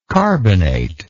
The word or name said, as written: carbonator